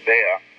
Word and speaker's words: Bea: That's spelled B-E-A, but it's pronounced Bear.